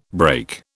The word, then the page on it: brake: British English